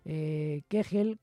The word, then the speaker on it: Köchel: Kegel con K, y se escribe Köchel, pero ellos dicen Kegel, ya sabéis que son muy, muy duros ellos, los alemanes.